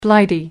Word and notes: blighty: American English

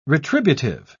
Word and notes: retributive: British English

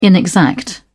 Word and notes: inexact: British English

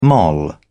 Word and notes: moll: British English

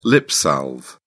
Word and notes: lipsalve: British English

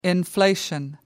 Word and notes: inflation: American English

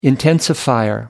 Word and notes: intensifier: American English

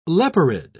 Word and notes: leporide: British English

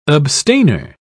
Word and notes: abstainer: British English

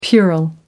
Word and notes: puerile: American English

